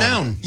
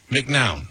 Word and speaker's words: McNown: I always say, because it's M-C-N-O-W-N. McNown. – Yes. So that is how I pronounce it and have pronounced it for my whole life. But I will say, I think the accurate pronunciation from, you know, my ancestry is probably McNown.